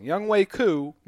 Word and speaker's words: Younghoe Koo: I think his name's actually Youngway. I always say it wrong. Youngway Koo missed 1 out of 1 attempt, so that is incorrect, though.